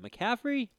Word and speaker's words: McAfee: McAfee.